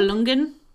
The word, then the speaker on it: Nibelungen: Nib- Nibelungen.